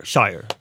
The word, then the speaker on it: shire: Ja, men jag fick höra då att när du säger, om du säger ordet Devon, att det uttalas ju som det gör. Men om du tar det där sista ordet och uttalar det för sig själv, så då är det Shire.